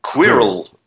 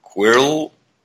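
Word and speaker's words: Qwilr: And the other one, it's almost impossible to pronounce, which is Quirrell. – Okay, how do you spell this? – I will tell you in an instant. Quirrell, and it's Q-W-I-L-R.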